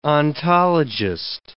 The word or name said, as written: ontologist